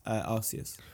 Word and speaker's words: Arceus: How is it pronounced? How do they pronounce it? – Arceus. – Okay, Arceus then.